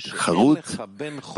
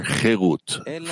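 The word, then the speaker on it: cherut (חרות): do not pronounce it charut, but rather cherut, freedom.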